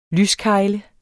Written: lyskegle

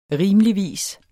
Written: rimeligvis

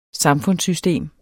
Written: samfundssystem

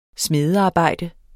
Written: smedearbejde

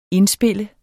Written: indspil

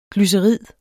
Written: glycerid

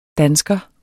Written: dansker

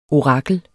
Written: orakel

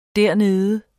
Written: dernede

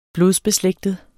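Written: BLODSBESLÆGTET

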